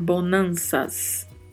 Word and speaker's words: bonanza: Bonanças, e em espanhol aí a particularidade da pronúncia do Z, tá? Nós não temos pronúncia de Z no espanhol. Sempre que houver uma palavra com a letra Z, vamos pronunciar com som de S. Então, por mais que a escrita aí seja bonanza, vamos pronunciar bonanças.